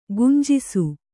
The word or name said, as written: ಗುಂಜಿಸು